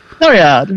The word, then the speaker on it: Floriade: Floriade.